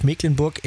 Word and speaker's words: Mecklenburg: Übrigens für alle Skeptiker, die immer noch sagen, ja, wieso Mecklenburg, das wird doch aber mit CK geschrieben. Gleich 7.10 Uhr erklärt uns Björn Berg vom Kreis Agrarmuseum Dorf Mecklenburg im Landkreis Nordwestmecklenburg, warum es dennoch Mecklenburg ausgesprochen wird.